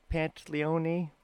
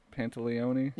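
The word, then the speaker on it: Pantoliano: Joe Pantaleone? – Yeah. Joe Pantaleone? – Yeah, you know him, guys.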